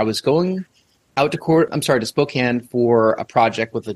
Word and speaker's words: Spokane: Did I say that correctly? Spokane? It's not Spokane, right? Spokane. – It's Spokane.